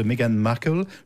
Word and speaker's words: Meghan Markle: Hein, l'histoire d'amour du prince Harry et de Meghan Markle, je prononce bien son nom ? – Oui.